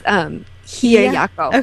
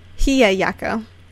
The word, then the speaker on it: Hiyayako: is Hayayako? Am I pronouncing that right? I feel like I'm pronouncing it completely wrong. – It's Hiyayako. – Okay. I wasn't sure. Hiyayako.